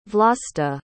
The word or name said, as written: vlasta